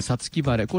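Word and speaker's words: 五月晴れ: さみだれというのはね、もともとはで 5 月に晴れと書いてさつき晴れ、これあの本来はですよ、昔は梅雨の晴れ間のことを指しました。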